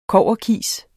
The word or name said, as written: kobberkis